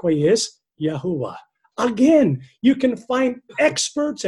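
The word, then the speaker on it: Yahweh: Greek transcriptions also indicate that YHWH should be pronounced Yahuwah.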